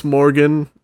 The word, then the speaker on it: Morgaine: I'm pretty sure it's Morgan. It's spelled M-O-R-G-A-I-N-E, which that's a really awesome spelling of that name.